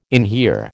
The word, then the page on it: inhere: American English